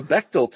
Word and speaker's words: Bechdel: The Bechdel test,